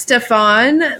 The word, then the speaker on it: Stefan: So Becca G's share for Pleurbeats is called Love on Fire by D-Block, and I think pronounced Stefan, but it's S-T-E-F-A-N.